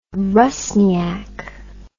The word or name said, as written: Russniak